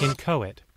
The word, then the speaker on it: inchoate: How do you even pronounce that? I gotta look that up. Oh, if I do it over here, it'll pronounce it. Come on. Hey, hey, some of yourself in your arch nemesis. Some of yourself in your arch. – Inchoate. – Ah, that was it.